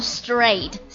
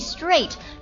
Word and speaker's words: strait: 来看第三个，第三个的话呢，它长得有点像那个 straight 那个单词，直行那个单词啊，直的那个单词，它的读音是完全一模一样的，也叫做 straight, straight. S-T-R-A-I-T straight 这个单词的话，作为名词呢没有什么好讲，它作为名词呢就表示海峡的意思，比如说这个白令海峡 The Bering Strait。